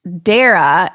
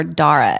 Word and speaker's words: Dara: So Lily is engaged to a beautiful girl named Dara. It rhymes with Sarah. This is how I have to remind myself how to say it. I want to call her Dara every time.